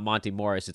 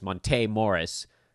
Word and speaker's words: Monte Morris: Someone told me lately that everybody's been saying his name wrong and that it's not Monty Morris, it's Monte Morris. And I feel bad about that, and I feel like we should have known because there's an accent on the E, but no one's ever really pronounced it.